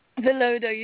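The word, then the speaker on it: Villoldo: Okay, before I get carried away, which I haven't got carried away yet, how do you pronounce Alberto's last name? – Velodo. You said it perfectly.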